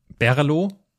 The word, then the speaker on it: BRLO: Schreibweise ist B-R-L-O. Das führt dazu, dass alle Leute sich fragen, hä, wie spricht man das denn aus? Äh, ich habe jetzt gelernt, es heißt Berlo und äh,